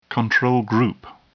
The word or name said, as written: control group